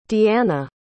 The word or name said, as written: deeana